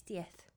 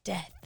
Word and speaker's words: diez: Oh, it's diez. – Diez. Wow, death. – No, not death. – Death. – It's spelled with a Z.